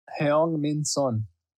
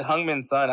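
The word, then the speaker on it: Heung-min Son: I'm fairly confident I pronounced that name right. – Heung Min Sun. – All right, there. – You could have just said Hung Min Sun. I'm pretty sure everybody knows.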